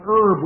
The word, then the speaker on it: herb: I know here when we talk about an herb, we don't pronounce the H, do we?